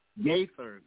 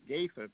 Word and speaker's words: Gaither: The Gathers family, G-A-I-T-H-E-R, for those of us looking. – The Gaithers, that's who you're talking about. – It's G-A, Gaither, how you pronounce it? – Gaithers. – Gaithers, thank you, Gaithers.